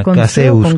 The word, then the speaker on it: caseus: Así, tal cual como suena, caseus, con S primero y con S después.